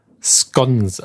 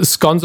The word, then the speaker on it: scones: Scones. – Also äh, Scones oder Scones, das kann man aussprechen, wie man möchte.